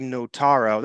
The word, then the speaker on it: Notaro: Live is the second album by American comedian Tig Notaro. That's how you pronounce that. – Notaro.